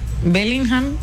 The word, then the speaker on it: Bellingham: Bellingham, ¿sí? – Sí.